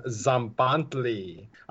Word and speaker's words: Tzompantli: So our first segment of the eight is called Zombantli. It just so you're aware, it's spelled T-Z-O-M-P-A-N-T-L-I. The literal translation for that word is skull rack.